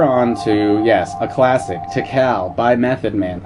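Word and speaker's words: Tical: I'm very glad to be pronouncing that correctly. I think last time I called it Tikal. And I don't know why, because he says Tikal, like, a lot.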